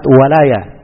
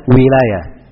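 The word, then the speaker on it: walayah (ولاية): Kalau wali itu dikatakan orang yang dapat walayah dari Allah Subhanahu wa taala. Iya. Maka bacanya walayah, bukan wilayah.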